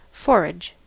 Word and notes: forage: American English

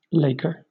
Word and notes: laker: British English

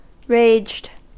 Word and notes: raged: American English

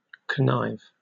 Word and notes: connive: British English

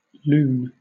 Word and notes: lune: British English

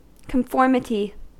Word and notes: conformity: American English

